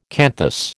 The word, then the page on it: canthus: American English